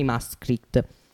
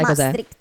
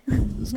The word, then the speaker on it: Maastricht: Adesso invece parleremo del trattato di Maastricht. Tu sai Maastricht. – Cos'è? – Maastricht.